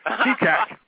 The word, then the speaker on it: Fecak: How do you say your name? – Very carefully. Feecat. – Feecat. You know, I got to tell you, it's F-E-C-A-K. And I know in Central Europe, the C makes a TS sound.